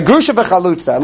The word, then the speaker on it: Grusha v'chalutza: So it's like the Mishnah uses all the time, Grusha of Almana. Am I saying it right? No, I'm not saying it right. Thank you. I knew I was trying to make a point. Right, Grusha v'chalutza.